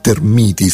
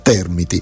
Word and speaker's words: termiti: forse invece che termiti bisognerebbe chiamarle a questo punto termiti, spostando l'accento. Però in effetti la parola termite riguarda un composto chimico facilmente infiammabile, insomma continuiamo così come abbiamo fatto sempre, con l'accento sulla prima sillaba, chiamandole appunto termiti,